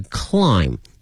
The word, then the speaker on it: climb: 同时啊，同学们记住了英文拼写的一个规律呢，如果说是以 MB 结尾的话呢，很多情况下这个 B 是不发音的，比如说爬这个词它的发音是 climb, 但是呢它的拼写里面会有一个 B, C-L-I-M-B, 因此呢同学看到这 MB 结尾的单词的时候呢一定要小心，很多情况下这个 B 是不发音的。